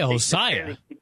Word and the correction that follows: Hosaka: Yeah, she used to be awesome, but you mean Malaya Hosaka? Hosaka. – Malaya Hosaka, I'm sorry.